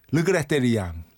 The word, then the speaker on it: regrette: 네 에디트 피아프의 Non, je ne regrette rien. – 어려운데? – 네. – Regrette rien. – Non, je ne regrette rien. 이게 R 발음이 히응하고 아라고 이 사이 발음이라 이게 되게 어려워요.